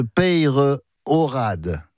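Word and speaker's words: Peyrehorade: Et je vous remercie de bien prononcer le nom de Peyrorade. On dit dans la région. On prononce le E et le Y, et je suis bien placé pour le savoir.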